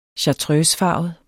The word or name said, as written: chartreusefarvet